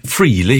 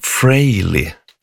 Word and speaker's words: Frehley: Nu säger jag Frehley, jag har sagt Frehley hela mitt liv, Ace Frehley, men sen efter att Ace hade trillat av pinnen och jag hör så massa reportage om honom så ska man tydligen uttala hans efternamn Frehley.